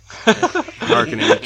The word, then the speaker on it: Harkonnen: Like, they're saying that they're, like, cargo ships and stuff that the Harkonnen Harkins. Showed up with. Harkonnen. Shit. Goddamn pronunciation police around here.